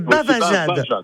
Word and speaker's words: bavajade: Alors déjà, vous dites bajavade. Alors c'est pas bajade, excusez-moi, mais c'est pas un problème. Donc c'est pour juste mettre la bonne prononciation. – C'est non, c'est pas un problème. – C'est bavajade. C'est bavajade.